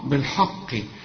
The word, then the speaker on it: بالحق: فاكر لما قلت لك تذكر وله الحاء الحمد، شوف وله الحمد، شوف الحاء هنا، الحاء هنا جيت وقلت ايه بالحاء بالحق، ما هي الحاء بتاعت الحمد هي نفس الحاء بتاعت الحق، لكن بالحق فيها حرف من الحروف المفخمة علشان كده معرفتش تنطق الكلمة صحيحة. لكن قل لي بالحاء الأول، بالحاء زي ما أقول لك إزاي الحال، واخد بالك؟ فقل لي بالحاء وبعدين فخم لي القاف بالحق.